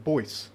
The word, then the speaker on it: Boyce: I think they were pronounced it Boyce. Geordie Boyce. Okay. That's how... – B-O-Y-C-E. Is the phonetic spelling.